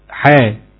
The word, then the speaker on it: حاء: اصلها حاء اخرها همزه، ولكن تنطق حاء بدون همزه خالص، تنطق حرفين بس حاء والف، وبرضك الطاء طاء والف، والراء راء والف، وهكذا.